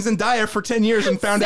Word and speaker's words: Zendaya: It's Zendaya. – It's Zendaya. Sorry. – Sorry, listeners, tune into our Challenger review where I was corrected on how to pronounce the name by like three people. But it is Zendaya.